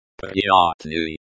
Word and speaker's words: приятный: This is pronounced приятный and the y at the end is very soft. Almost like a year.